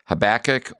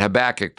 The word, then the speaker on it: Habakkuk: Well, now we move on to the next book for this week, and that would be Habakkuk or Habakkuk, depending upon how you want to pronounce it. I know that we've talked about pronunciations earlier. This is one of those books that depend upon who you listen to, how you pronounce it. But Habakkuk probably is a little bit more accurate, but Habakkuk is also the way some people would pronounce it.